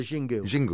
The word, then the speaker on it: Xingu: When you were there, and is it Xingu? Do I say it right? – The Xingu. Xingu.